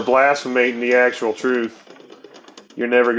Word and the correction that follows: blaspheming: Blaspheming the truth.